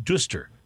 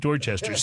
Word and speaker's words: Dorchester: And then there's a town inside, like, the greater Boston area called Dorchester, but you do not pronounce it Dooster. You pronounce it Dorchester.